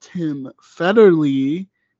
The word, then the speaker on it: Federle: And but this is like the actual real, and that's really a testament to not Tim Federle, but Tim Federle, who is how you pronounce it, which I looked it up before recording this, because Rachel always said Tim Federle, and it made me laugh.